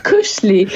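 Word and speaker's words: koselig: A propos tego cynamonu, takie pojęcie, czy tam koncept kulturowy Kosselig, to się pisze Kosselig, nie wiem jak się wymawia, Kosselig chyba. – Widzieliśmy! – Jeszcze raz, jak się mówi? – Kuszli. Kuszli, czyli to ta przyjemność.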